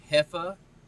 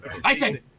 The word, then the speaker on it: Hefeweizen: No, because the Germans have a whole different way they say W as a V. Crazy, right? I know. – What is the correct pronunciation of this? – So it's spelled with a W in the middle, but it starts with an H. Hefeweizen. – Weizen.